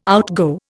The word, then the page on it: outgo: Indian